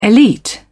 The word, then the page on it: elite: British English